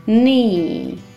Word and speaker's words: knee: Knee – колено. k пишется, но не читается.